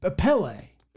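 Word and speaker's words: Pelé: So, it's Pele. – So, I think it's Pele. It's pronounced Pele, yeah.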